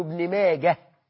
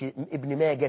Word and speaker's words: ابن ماجه: هو اسمه ابن ماجه هاء في الآخر مش تاء، هاء ابن ماجة، حتى في الوصل تقول هاء ما تقولش ابن ماجة تاء، لا غلط.